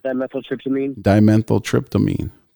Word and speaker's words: dimethyltryptamine: They said the house was used to produce the hallucinogenic drug, diptyltryptamine. Is it? Did I say that right? Diptyltryptamine. Yeah, DMT. – Dimethyltryptamine. – Thank you. So dimethyltryptamine, and I've never had it, but I've known people that have smoked it in this form out of a vape pen.